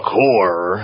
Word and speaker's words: Corre: And The Core, as we're pronouncing it still, even though it's now spelled C-O-R-R-E, for those at home with your spell check going, that ain't right, dude.